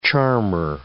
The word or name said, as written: charmer